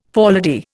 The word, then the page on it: forelady: Indian